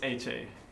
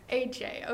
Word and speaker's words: AEGEE: How do you pronounce it? it's HA. – AJ, all right.